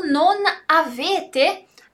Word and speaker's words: non avete: O non avete, ele juntou a pronúncia. Non avete, a gente vai falar como se fosse uma palavra só.